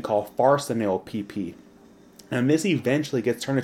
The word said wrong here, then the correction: farnesyl: And how would you pronounce that? – Farnesyl-PP and dolichol. I think, yeah, dolichol. – Dolichol? Right. Okay, yeah, because it's a weird-looking word.